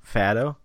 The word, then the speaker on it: Fado: Fado, like F-A-T-T. – F-A-D-O. Fado. That's how you pronounce it.